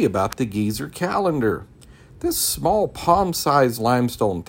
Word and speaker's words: Gezer: And we're at the famous Geezer calendar, not like an old geezer, but G-E-Z-E-R. Some might would pronounce it Geyser, but Geezer calendar.